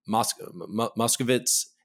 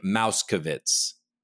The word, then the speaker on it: Moskovitz: District Court for the Southern District of Florida at one point asking Armstrong to correctly pronounce, Moskovitz instead of saying Mouskovitz.